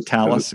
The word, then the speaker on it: Thales: But so in 2010, then you signed your contract with do I pronounce that right? Telus? – Yeah, Thales Alenia. – Thales, excuse me.